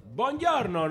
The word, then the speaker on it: buongiorno: Bon dia. Buongiorno, ¿no? – Buongiorno.